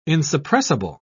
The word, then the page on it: insuppressible: British English